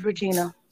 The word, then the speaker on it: Regena: My given name is Regina. It means queen. It's not spelled with an I, but an E. So R-E-G-E-N-A is my name.